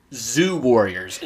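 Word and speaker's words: Zu Warriors: Zoo Warriors.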